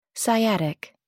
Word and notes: sciatic: American English

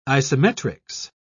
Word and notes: isometrics: British English

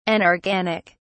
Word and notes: anorganic: British English

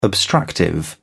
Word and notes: obstructive: British English

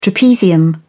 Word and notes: trapezium: British English

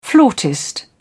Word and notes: flautist: British English

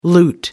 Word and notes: loot: American English

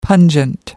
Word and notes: pungent: American English

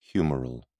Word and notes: humeral: British English

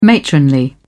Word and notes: matronly: British English

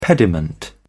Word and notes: pediment: British English

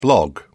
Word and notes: blog: British English

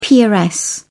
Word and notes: peeress: British English

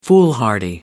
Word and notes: foolhardy: American English

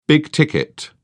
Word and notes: big-ticket: British English

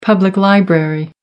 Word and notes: public library: American English

